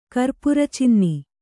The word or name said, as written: ಕರ್ಪುರಚಿನ್ನಿ